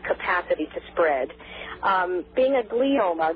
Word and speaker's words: glioma: a malignant glioma.